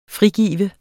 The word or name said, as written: frigive